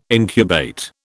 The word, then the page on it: incubate: British English